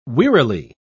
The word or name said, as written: wearily